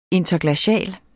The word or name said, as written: interglacial